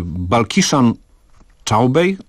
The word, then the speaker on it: Balkishan Chaubey: Otóż pan Hindus Balkishan Chaubey, tak to się chyba wymawia. – Bardzo dobrze pan to wypowiedział.